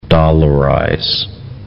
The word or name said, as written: dollarize